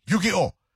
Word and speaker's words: Yu-Gi-Oh!: Yu-Gi-Oh! That's how you say it, Mateo. Yu-Gi-Oh!